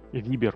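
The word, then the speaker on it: Viber: Ну вот зачем нужен Вайбер? Вибер, вот. правильно говорить.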